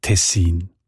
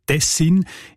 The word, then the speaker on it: Tessin: Und auch im Hochdeutschen sieht man Tessin. Die Variante mit der Betonung auf der ersten Silbe und mit kurzem I, also Tessin, ist seltener.